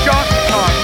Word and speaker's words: John Con: It's John Con. My name's John. It rhymes with Con. Con, Con, John Con.